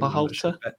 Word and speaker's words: Berhalter: Greg Berhalter, if that's how you pronounce his name. Berhalter. That's it.